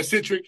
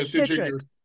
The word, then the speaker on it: Citric: Yeah, Citric. Yeah, Citric. Yeah, it's like citric acid.